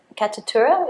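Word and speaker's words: Katutura: We watched the video on your website at the Catatura. Is that the right way to say it? – Yeah.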